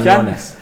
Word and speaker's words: Quiñones: Neler burada konuşurken acaba bu oyuncu nasıl okunuyor diye konuşuyorduk. Quiñones mi okunuyor derken Quiñones.